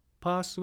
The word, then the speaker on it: PaaS: PaaS は Platform as a Service の略で PaaS と発音しています。